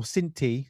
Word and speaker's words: Cinthie: So, and uh, someone, or Cinthie, there's no H, the H is silent apparently.